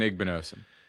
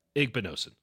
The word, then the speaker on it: Igbinosun: Davison Igbinosun. – Doesn't have the frame. But my God, is he a scrapper. Like Igbinosun, right? Igbinosun. Thank you. I'll get it right.